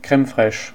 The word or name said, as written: Creme fraiche